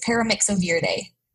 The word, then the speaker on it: Paramyxoviridae: No, Rinderpest is Paramyxoviridae. – Jesus. First try, you said that right.